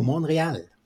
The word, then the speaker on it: Montreal: So please tell our listeners all about your exciting trip to Montreal. – Yeah, Montreal, that's a good pronunciation.